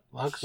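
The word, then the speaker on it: LUKS: Lux. How do you pronounce it? – Lux.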